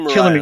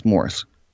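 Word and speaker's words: Morais: Well, Marias was actually for me, but second to Marias. – Killing me with that Marias. It's Morris.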